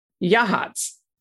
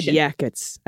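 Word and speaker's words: Yachats: Another difficult pronunciation. Any guesses? Answer is Yahats. Oh. Yahats. Yahats. Yahats. – And yeah, I would have said yakets. I would have said yachts.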